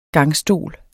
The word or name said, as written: gangstol